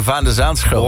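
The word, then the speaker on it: Van de Zandschulp: Ja, ik vind dat ze zijn naam ook goed uitspreken. Van de Zaanschulp.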